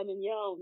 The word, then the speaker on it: Mignot: It's part of the reason why I go by Mignot now. Like, the correct pronunciation, that's like, you know, that's the correct pronunciation of my name, not the Americanized version.